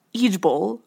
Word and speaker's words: IJBOL: So now the internet wants to revitalize laughing online, apparently, with a new term, IJBOL, pronounced IJBOL, according to this article.